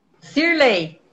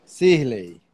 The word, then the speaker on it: Sirley: Como é que fala teu nome, Sirley ou Sirley? Sirley. Sirley.